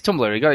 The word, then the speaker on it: Tumblr: How do you pronounce that thing? I've always seen it. I have no idea how it's... – You talking about the website, Tumblr? – Tumblr? Is it Tumblr? Oh, it is Tumblr.